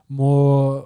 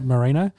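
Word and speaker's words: Moreno: Yes, he's the current champion, and he's rolling back a fight that they had with Brandon Moore. How do you say that? – Marino.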